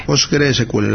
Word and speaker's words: PostgreSQL: Tenemos otro curso también que me ha llamado la atención, el curso de bases de datos y Process SQL de Víctor Dorantes. – PostgreSQL, lo he dicho mal, pero bueno, lo repito.